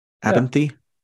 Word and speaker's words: Abenthy: Is that how you pronounce it? Abenthy? – Yeah.